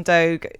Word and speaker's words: Doge: We don't really know how to pronounce Doge coins, Doge coins, Doggy coins, Doggy coins. Well, we've had this discussion before and I've settled on Doge.